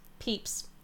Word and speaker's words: Pepys: Is it Pepys? Is that how you pronounce it? It's Samuel Pepys. – Peeps. That makes much more sense than I was going to say.